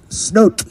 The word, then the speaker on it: snoot: This next one is pronounced snoot. Snoot. Means dry land spot.